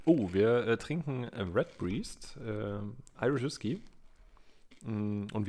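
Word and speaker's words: Redbreast: Und äh, mit äh, der Red, Bre Red Breast hast du mir gerade eben. Ich hätte das jetzt so ausgesprochen, ja. ja. – Wie die Breast. Breast, okay. Ja.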